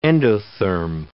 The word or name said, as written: endotherm